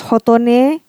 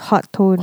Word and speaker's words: Hotone: I thought it was pronounced hotone. – Hotone. – Because it's, you know, macam it sounds like Japanese. Yep. It's actually Chinese. And it's pronounced hot tone.